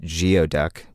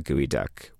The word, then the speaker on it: geoduck: And the name geoduck is commonly mispronounced geoduck, which is how it's spelled, G-E-O-D-U-C-K,